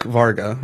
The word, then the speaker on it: Varga: Chuck Varga, right? That's yes. – How you say it.